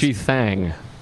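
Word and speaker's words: G Thang: I believe it's G-Thang. – Well, he actually... – It's an A. He's right. They would always spell it T-H-A.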